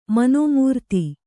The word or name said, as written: ಮನೋಮೂರ್ತಿ